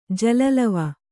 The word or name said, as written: ಜಲಲವ